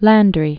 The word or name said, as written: Thomas Wade Landry